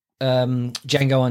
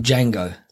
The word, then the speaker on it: Django: And he says, "I'm Django." And he and then he goes quiet and he goes, "The D is silent."